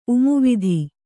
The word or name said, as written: ಉಮುವಿಧಿ